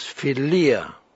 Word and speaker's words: philia: The next word is philia.